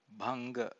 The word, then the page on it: bhanga: Pali